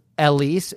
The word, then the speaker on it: Ellice: Her name is Elise. It's spelled E-L-L-I-C-E.